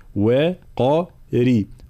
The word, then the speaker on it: وقري: كلمة قري، الانتباه لابد من التمرن على نطق الراء المرققة بعد حرف مفخم مثل هذه الحالة، قاف مفخمة ثم الراء مكسورة مرققة، فلا نقول وقري، لا وقري وقري وقري عين هكذا.